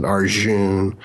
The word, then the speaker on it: Arjun: Like if you looked at Arjun, it would be J-U-N. So you'd say June, but it's, you know, I pronounce it Arjun and Hair Jar and things like that.